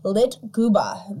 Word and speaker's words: Lyktgubbe: So the Swedish believe the floating lights are called Lyktgubba. That is spelled Lyktgubbe. And I had to go online to, like, know how to pronounce it. Lyktgubba.